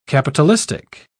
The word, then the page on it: capitalistic: British English